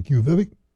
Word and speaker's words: Quviviq: Quviviq? And if you look at how it's spelled, you'd never think that's how it's pronounced. Quviviq.